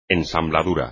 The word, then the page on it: ensambladura: espagnol